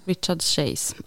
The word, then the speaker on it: Richard Chase: Ja. Richard Chase. Uttalar hans namn rätt nu då? – Efter... Richard Chase. – Richard Chase, ja.